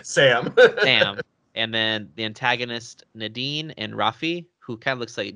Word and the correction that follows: Rafe: I think it's pronounced Rafe.